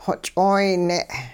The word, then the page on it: xoch'ooyne': hupa